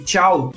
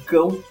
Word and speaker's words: Cao: Esse tchau da Tchau La Rue se escreve C-A-O, como se fosse cão, mas não é cão, nem caô, é tchau. C-A-O-L-A-R-U.